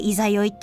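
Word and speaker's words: 十六夜: 16 夜って書いて「いざよい」って読めますけれどもいざよいは満月から今度はまたかけ始める反対側に向かってこうかけ始めるお月様なんですけどこれもまたいいと。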